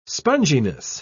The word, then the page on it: sponginess: British English